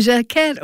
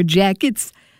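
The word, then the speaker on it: Jacquet: Illinois Jaquette or Illinois Jackets, depending how you pronounce it.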